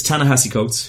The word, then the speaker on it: Ta-Nehisi Coates: But it's Ta-Nehisi Coates, who is a famous correspondent for the Atlantic newspaper in the States.